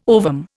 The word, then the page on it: ovum: Indian